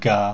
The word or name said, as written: ga